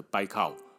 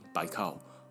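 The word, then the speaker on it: 白 靠 (Байкал): 如果把它念成台语啦，北海北海，或国语的北海北海，听起来跟现在的白靠，就是贝加尔湖的发音白靠，不管是俄文发音还是布里亚特人的发音，白靠白海北海，是不是有点像？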